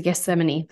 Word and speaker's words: Gethsemane: If you read the scripture and any of the accounts on um, uh, is a Gethsemane.